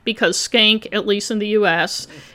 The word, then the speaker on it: Shenck: And by the way, before I get too far in this, the high school is spelled S-H-E-N-C-K, and it's pronounced skank.